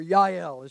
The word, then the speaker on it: Jael: Or Yael is pronounced in Hebrew.